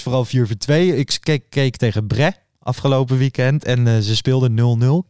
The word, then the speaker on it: Brest: Bre zeg je denk ik. Brest is het geschreven. – Ik ben niet zo goed in die Franse uitspraak.